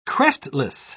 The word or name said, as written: crestless